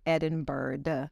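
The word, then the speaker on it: Edinbyrd: My last name is spelled E, D as in David, I, N as in Nancy, B as in boy, Y-R-D, pronounced Edinburgh. – Edinburgh, not Edinburgh, yes, like Scotland, but Edinburgh.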